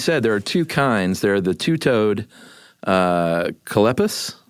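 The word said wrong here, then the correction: Choloepus: Coleoepus. Coleoepus. – Coleoepus? – It rhymes with Bocephus.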